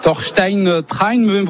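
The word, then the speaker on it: Torstein Træen: Thorstein Train, vous l'avez bien prononcé effectivement.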